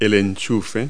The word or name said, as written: plug